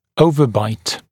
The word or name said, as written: overbite OB